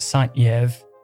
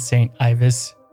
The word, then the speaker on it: Saint-Yves: And it was pronounced Saint-Yves, not Ivis. I was saying Saint Ivis.